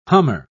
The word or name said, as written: hummer